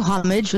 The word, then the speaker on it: homage: I had to ask, British culture would say homage, Americans say homage. So mm-hmm. Which do you prefer? – I go homage with the H, and it was all the alliteration because, of course, you know, it all just flowed quite nicely.